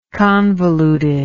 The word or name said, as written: convolutedness